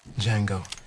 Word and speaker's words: Django: Django. D-J-A-N-G-O. The D is silent.